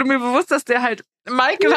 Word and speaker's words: Meikel: M-E-I-K-E-L, Michael. Weil wir dann noch so, okay, klingt wie Michael, so.